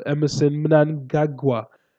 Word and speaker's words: Emmerson Mnangagwa: Emmerson Mnangagwa, that's his name.